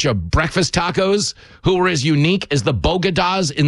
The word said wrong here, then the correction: bodega: the Bronx, except there's only one small problem. It's pronounced bodega.